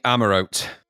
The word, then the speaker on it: Amaurote: Is Amarote? Amarote? How do you pronounce that? – I'm saying Amarote.